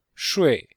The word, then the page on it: shui: shui4